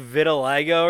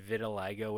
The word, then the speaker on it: vitiligo: Like, is that how you pronounce that? That's a word I've only seen like written. I've never heard anybody say it. – Yeah, I believe it's called vitiligo.